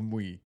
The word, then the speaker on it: Muy: M-U-Y. All right, so the vampire of Muy. I'm not joking. – Like vampire of Muy. – No, it's Muy. – Muy. That's how you pronounce it, because it's French.